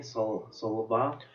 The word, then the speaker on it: Salva: Victor Salav is spelled S-A-L-V-A, Salva, is that how you pronounce it? – S-A-L-V-A would probably be Salva. – Salva, okay, there we go.